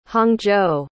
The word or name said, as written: hangzhou